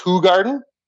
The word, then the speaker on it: Hoegaarden: Another one that I have not figured out how to replicate or get close to is, it's pronounced Hoegaarden.